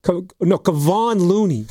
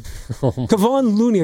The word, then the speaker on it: Kevon Looney: I think his name is Kevin Looney? Kevon Looney. Kevon Looney. I think that's how you pronounce it.